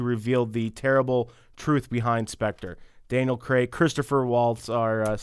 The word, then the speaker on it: Spectre: Yeah, you're saying it right. Okay. Spectre.